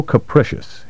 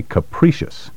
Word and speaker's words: capricious: Note the difference in pronunciation between capricious and caprice. Don't say capricious. Capricious should rhyme with delicious. Word 12. Blatant. B-L-A-T-A-N-T.